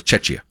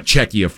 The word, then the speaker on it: Czechia: It's C-H-I-A. – But it's pronounced with a K. Czechia. See, I always thought it was Chechia.